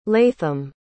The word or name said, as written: latham